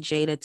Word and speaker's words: Jaida: So, you guys can follow me on Instagram at the Jada Taylor. My name is spelled J-A-I-D-A.